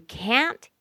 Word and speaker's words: can't: Okay, but the T is often not pronounced, so you can't hear the word can't. You'll never hear the T on the end of that.